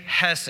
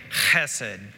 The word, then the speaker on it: hesed: It kind of rhymes with blessed. So say hesed. Yes. Great. So technically, if you're wanting to be really Hebrew, you would say chesed.